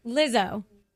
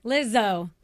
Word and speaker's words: Lizzo: Lizzo. – Okay, but there's a Z, but there's like a soft S on the Z. – Yeah. – When I say it, there is.